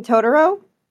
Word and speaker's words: Totoro: The girl and the one that looks like Torturo. It was that one. – You mean Totoro? – Yeah, I don't know how to say his name. – What? Yeah, Totoro.